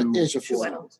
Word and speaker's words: Ejiofor: I can always see the letters, but I never know how they combine to Ejiofor.